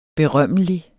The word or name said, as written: berømmelig